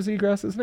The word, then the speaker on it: Zegras: Are you purposefully saying his name wrong? – How am I supposed to pronounce Trevor Zegras's name? – Zegras. – Are you sure about that? – It's not. There's no ass in his name.